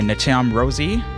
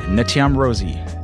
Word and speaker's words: Ntjam: So spelled S-A-U-X. After that, we had Space of You by Natam Rosie. I had to go on YouTube real quick to see if I could find somebody who actually pronounced her name, because it is spelled N-T-J-A-M. Natam Rosie from Denmark by way of Cameroon.